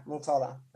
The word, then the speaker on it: Motala: Motala.